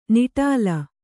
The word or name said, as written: ನಿಟಲ